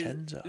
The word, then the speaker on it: Vicenza: So his Vicenza.